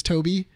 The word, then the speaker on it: Tobi: It's considered Toby, but it is also T-O-B-I. – Okay. – Because it's technically an android. – Yeah. – So the way that they pronounce it is always Toby, but...